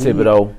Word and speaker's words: Thibodeau: But he, Thibodeau.